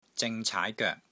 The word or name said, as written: ZING3 CAAI2 GOEK3